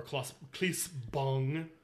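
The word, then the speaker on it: Claes Bang: who is played by Klaas Bang, or Klaas Bong, however he pronounced it. It's definitely Bong.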